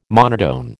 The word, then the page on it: monotone: American English